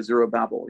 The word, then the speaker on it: Zerubbabel: They're really from a second generation because the first exiles go back with Zerubbabel, or as some people say, Zerubbabel, you know, it's a pronunciation in English.